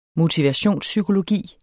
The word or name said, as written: motivationspsykologi